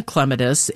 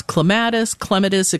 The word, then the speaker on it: clematis: It depends on the clematis, and you can pronounce it any way you'd like. It's clematis. It kind of depends on where you're from as to how you pronounce it, but both are correct.